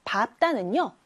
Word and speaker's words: 밟다: 그런데 똑같이 리을 비읍인 받침 밟다는요, 뒤에 있는 자음, 즉 비읍으로 소리가 난다는 것.